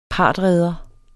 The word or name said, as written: partredere